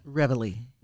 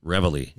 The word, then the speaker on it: Reveille: Reveille. – Reveille.